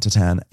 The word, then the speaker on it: tatin: I was actually thinking tartine. – No, I think it's tatin. – Is he making a tart? – Is tatin? – Is it spelled tartine? – No, it's spelled T A T I N.